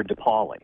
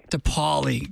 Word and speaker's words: DiPoli: It's Pastor DiPoli. – DiPoli. God forgive me. I will get that correct. I'm going to write it down. While I'm writing down how to pronounce DiPoli. It's like DePaul, the school, though. I should be able to remember that.